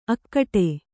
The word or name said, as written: ಅಖೋಟ